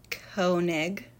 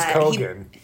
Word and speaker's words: Keoghan: So basically, we have Barry Keoghan, Irish. – I think it's Keoghan. – Keoghan.